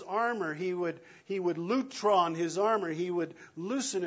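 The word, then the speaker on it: lutron (λύτρον): And when we do that, eventually we will come to a little Greek word pronounced lutron.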